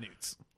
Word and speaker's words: Knut: Slap Newts. – No. Does it have a silent G in there? – It's a silent K. – What? – K-N-U-T.